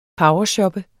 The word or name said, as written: powershoppe